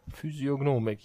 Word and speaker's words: Physiognomik: Habe ich das richtig ausgesprochen? Physiognomik, ja.